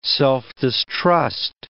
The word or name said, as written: self-distrust